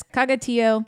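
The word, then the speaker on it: Cagatió: C-A-G-A-T-I-O. So okay. Like Tio, like uncle almost, but the accent is on the O, which is why it's bothering me because Tio in Spanish is T accent on the I-O.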